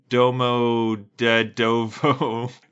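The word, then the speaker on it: Domodedovo: Domo de Dovo. Domo de Dovo. Domo de Dovo. That's kind of fun to say. Domo de Dovo. – Domo de Dovo. – Domo de Dovo.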